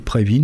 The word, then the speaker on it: Previn: Il faut prononcer Previn, nous on aurait plutôt tendance à dire Previn, hein. Mais c'est parce que c'est un musicien d'origine russe au départ,